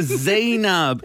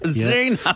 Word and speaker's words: Zeinab: No. It's Zainab. Zainab.